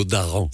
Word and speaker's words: daran: Daran, ouais, c'est plutôt Daran. – C'est Daran, pardon. Ouais. Sinon, je l'ai mal prononcé tout à l'heure.